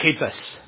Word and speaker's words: Cephas: And Cephas or Cephas, you want to say it right, Cephas. But or at least that's what we're told. That's how it's said.